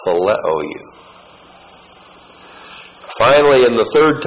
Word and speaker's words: phileo: But every single time that Peter answers, he answers with the verb phileo. P-H-I-L-E, long O.